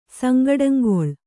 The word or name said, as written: ಸಂಗಡಂಗೊಳ್